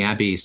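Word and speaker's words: Niabe (ニャビー): Niabe is believed to be a cat, rhymes with tabby.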